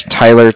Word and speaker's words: Tyler: Again, my name is Tyler, T-Y-L-E-R, and I'm calling with Cardiff. It's spelled C-A-R-D-I-F-F, as in Frank Frank.